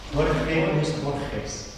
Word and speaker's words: Borges: Borges.